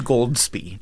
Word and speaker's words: Goldsbie: Oh, and my name is Goldsbie. G-O-L-D-S-B-I-E, pronounced exactly like it's spelled.